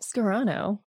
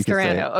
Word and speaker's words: Scarano: I always bring up the Sopranos. Like, however you say the Sopranos, got it. Would be how you say my name. So if you say Sopranos, you can say Scarano.